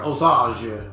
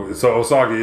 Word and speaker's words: Osage: I mean, that's not how you really say it, but Osage. Like, right. – That's how the French said it. – So Osagi is wrong.